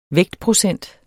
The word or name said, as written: vægtprocent